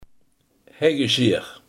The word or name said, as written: heggeschaar